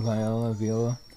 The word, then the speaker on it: Viola: It is by George Abarna and Frank, I guess you say Viola, is that how you pronounce it? – Yeah, Viola.